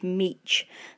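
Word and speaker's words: Mech: One other correction I should add here is I believe that David Meek, who wrote the book about wolves, his name should actually be pronounced David Meech.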